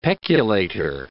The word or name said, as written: peculator